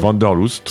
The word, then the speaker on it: Wanderlust: Wanderlust. – Wanderlust.